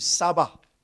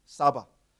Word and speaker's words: tsaba: The word hosts in the Hebrew is Sabah. T, T is silent. Uh, T S A B A, Sabah.